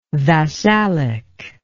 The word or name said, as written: vassalic